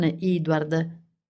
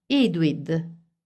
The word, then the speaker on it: Edward: Pronunciava il nome di Edward non Edward, bensì Edwid. Di fatto, la maggior parte degli abitanti della colonia, compresa la madre di Edward, Carly, nativa di Brooklyn, e quindi lo stesso Edward, pronunciavano Edward Edwid.